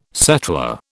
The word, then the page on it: settler: British English